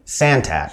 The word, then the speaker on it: Santat: Santat.